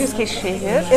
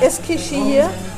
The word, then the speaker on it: Eskişehir: Sagen Eskişehir. Eskişehir.